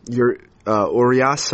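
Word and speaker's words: Urías: Luis Urias? – Yep, that's another one. – Or Urias? Urias? Mm-hmm. Urias? Urias? – Urias, I'd say, like Julio Urias. Same pronunciation.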